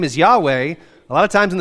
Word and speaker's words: Yahweh: We often pronounce it Yahweh, which even that pronunciation is something of an approximation. The pronunciation Yahweh is our best guess. I had a Hebrew professor at RTS who was very insistent that it's Yahweh. And he said, that's how Moses pronounced it.